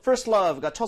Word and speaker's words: first love: 지금 발음 들어보니까 T 사운드가 거의 나지가 않았어요. – 그렇죠. – 그렇죠? 중간에 sandwich가 되면서, 자음 사이에서 sandwich가 되면서 거의 날아가 버리죠. 사실 얘는 first에 I에다 강세만 제대로 주면 이거 해결이거든요.